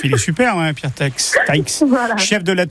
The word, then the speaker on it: Taïx: C'est Taïx, je crois, non ? – Oui, voilà, T-A-I-X.